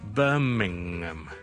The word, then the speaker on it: Birmingham: Ham 读埋个 H-M，咁但系咧，其实好多时候咧，我哋咧，喺地名里面嘅 H-A-M 咧，就未必会读个 H 嘅，咁我哋就读成咧 Birmingham，听到咗，Birmingham，OK，明后面系 N-G-M，楞埋落去嘅唔到就变咗 Birmingham，就唔需要咧系读成 Birmingham 嘅。